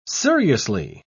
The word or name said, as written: seriously